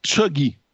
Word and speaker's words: cheugy: Et ce mot, euh, c'est le mot chuggy. OK? Alors, chuggy, là, ça s'écrit C-H-E-U-G-Y.